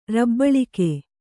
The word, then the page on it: ರಬ್ಬಳಿಗೆ: kannada